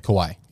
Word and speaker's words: Kauai: 1,400 acres on a ranch in Kauai? Is that how you pronounce it? – I don't know how that exists. Yeah, Kauai. – Kauai, yeah.